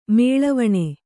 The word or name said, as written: ಮೊಳೆವಣೆ